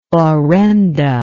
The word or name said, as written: orenda